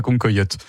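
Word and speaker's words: Cancoillotte: Oui, parce que vous nous parlez de la nouvelle star des réseaux sociaux et c'est justement la Concoyote. – Exactement, vous le prononcez très bien et beaucoup mieux que moi, Charles.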